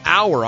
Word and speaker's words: Auer: Joe Auer, I'm going to say that's how you pronounce his name, A-U-E-R, returned the opening kickoff 95 yards for a Miami touchdown.